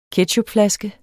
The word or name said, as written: ketchupflaske